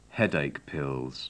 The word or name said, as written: headache pills